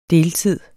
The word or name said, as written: deltids